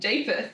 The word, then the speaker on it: Japheth: And he had three sons, Shem, Ham, and Japheth. – We literally had to look up on YouTube how to say it's like the Jaffa cakes.